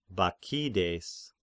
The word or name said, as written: Bakchides